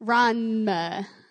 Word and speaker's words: Ranma: And then my friend introduced me to Ranma One Half, the manga. – Ranma? – Ranma. – All right, spell that. – R-A-N. – R-A-N.